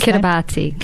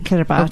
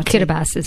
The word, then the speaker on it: Kiribati: For the other island community, could you just pronounce Kiribati? – Kiribati. – Or Kiribati, sometimes people say.